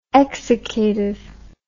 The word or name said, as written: exsiccative